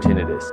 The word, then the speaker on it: tinnitus: This is Pure Tinnitus. Hey, audiologist and tinnitus specialist in California. The correct way to pronounce the word is tinnitus.